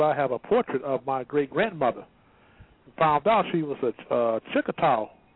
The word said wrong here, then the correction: Choctaw: if I'm pronouncing the word right. – Right, Choctaw. Yeah. – Choctaw.